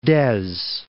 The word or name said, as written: Dez